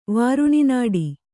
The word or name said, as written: ವಾರುಣಿನಾಡಿ